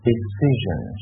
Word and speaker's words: decision: S-I-O-N 到底是怎么读的？好，注意一下，就是 decision 是吧？decision 啊，就是 D-E-C-I-S-I-O-N decision 这个词一定要注意的哈，decision 哈，人是吧？S-I-O-N。